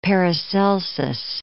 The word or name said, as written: Paracelsus